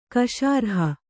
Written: ಕಶಾರ್ಹ

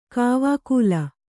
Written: ಕಾವಕೂಲ

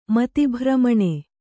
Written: ಮತಿಭ್ರಮಣೆ

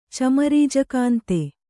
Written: ಚಮರೀಜಕಾಂತೆ

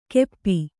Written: ಕೆಪ್ಪಿ

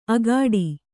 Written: ಅಗ್ಗಾಡಿ

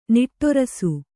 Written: ನಿಟ್ಟೇರಿಸು

